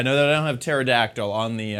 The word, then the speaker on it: pterodactyl: Pterodactyl. The P is silent.